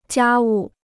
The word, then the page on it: 家务: jiā wù